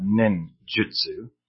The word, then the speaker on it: ninjutsu: The next thing is the U in jutsu. It is never pronounced I. You see some people writing ninjutsu as ninjitsu, as a Brazilian jiu-jitsu.